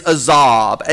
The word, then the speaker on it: azab: The Hebrew word for forsaken is azab. A-Z-A-B is how we would spell it in English.